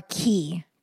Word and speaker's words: Quay: Oh, the ones in the picture I posted are Key. Q-U-A-Y. It looks like Quay, but it's pronounced Key.